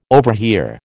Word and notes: overhear: American English

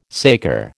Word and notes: saker: American English

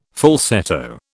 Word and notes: falsetto: British English

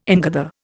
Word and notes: ingather: Indian

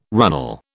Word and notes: runnel: British English

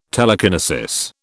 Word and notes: telekinesis: British English

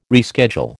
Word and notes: reschedule: American English

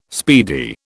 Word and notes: speedy: British English

